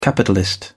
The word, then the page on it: capitalist: British English